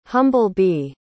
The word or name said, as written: humble-bee